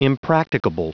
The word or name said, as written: impracticable